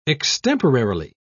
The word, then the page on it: extemporarily: British English